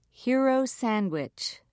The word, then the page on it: hero sandwich: British English